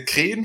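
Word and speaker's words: Kren (хрен): Auf Russisch heißt der Kren fast genauso ausgesprochen. Kren. Immer noch Kren. ich kann ja kein Russisch lesen, aber hier steht halt dann, ja, jetzt wird es halt mit CH geschrieben.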